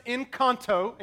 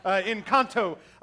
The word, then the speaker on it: Encanto: So it's pronounced Encanto, in case you don't know.